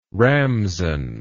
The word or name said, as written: ramson